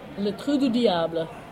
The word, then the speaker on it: Le Trou du Diable: Le Trou du Diable.